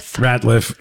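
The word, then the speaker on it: Rateliff: That is Nathaniel Radcliffe. – No, you said it wrong again. Ratliff and the Night Sweats.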